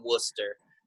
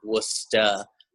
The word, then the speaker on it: Worcester: She's just like, if you want to live here, you got to know how to say it right. And she's like, but don't say it like you're from Boston. Say it like you're from Worcester. And I was like, well, how do people say it from Boston? And she's like, Worcester.